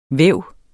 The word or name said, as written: væve